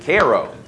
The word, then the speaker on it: Cairo: Please do not make the mistake of calling it Cairo. Cairo is in the other Egypt. All right. So if you go to Illinois, make sure, pronounce it Cairo.